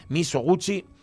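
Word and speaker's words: Mizoguchi: que seguramente no se pronuncia así, porque es de la de, es una película japonesa de Kenji Miyo, Miyo, Misoguchi, Kenji, Sube la música, por favor.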